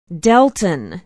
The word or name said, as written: delton